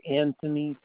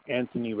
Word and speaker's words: Anthony: Yeah, my name is Anthony Caputo. You want me to spell that? – Yes, please. – Anthony regular, A-N-T-H-O-N-Y.